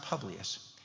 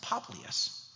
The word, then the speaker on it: Publius: And actually, it's pronounced Publius, and the Greeks pronounce it Poplius.